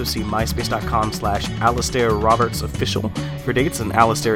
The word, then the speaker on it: Alasdair: Before that, Alasdair Roberts, a song called Hazel Forks off his album Spoils.